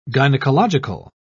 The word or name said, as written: gynecological